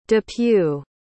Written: depew

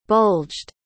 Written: bulged

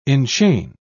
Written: enchain

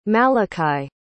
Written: malikai

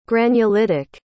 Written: granulitic

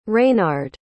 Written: Raynard

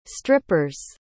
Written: stripper's

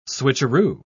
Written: switcheroo

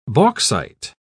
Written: bauxite

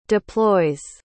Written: deploys

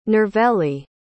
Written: nirvelli

